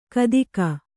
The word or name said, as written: ಘಾತುಕ